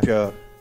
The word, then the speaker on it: Björn: La pronuncia allo svedese ogni volta mi, mi stupisce, mi meraviglia, mi strabilia. Björn. – Björn.